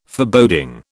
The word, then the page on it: Foreboding: British English